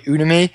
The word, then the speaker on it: Udemy: Udemy.